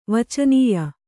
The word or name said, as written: ವಚನೀಯ